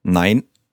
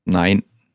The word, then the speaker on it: Nein: Nein. – Nein? – Nein.